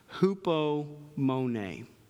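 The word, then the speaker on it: hupomone (ὑπομονή): And that's simply a word that's pronounced hupomone. Hupomone.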